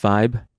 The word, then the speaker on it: Vibe: It's spelled like vib, but it's pronounced vibe.